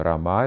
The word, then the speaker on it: Рама: Рама, удар, ударение на первый слог, мужское имя. Рама — это женское имя.